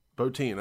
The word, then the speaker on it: Bottin: How do you pronounce it? Bottin? But Rob Bottin, yeah. – Bottin, okay.